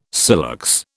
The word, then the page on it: silex: British English